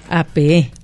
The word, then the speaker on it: apeé: A la segunda, apeé. – Apeé. – Ah, porque es pasado. Ajá, uh-huh, apeé. – Con, uh-huh, doble E y con acento en la última.